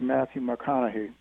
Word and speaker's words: Matthew McConaughey: I was I recently saw an interview with Matthew McConaughey.